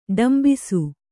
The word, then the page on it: ಡೊಂಬಿಸು: ḍombisu